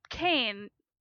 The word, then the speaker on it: Kane: And in fact, Kane rhymes with Wayne, mm-hmm.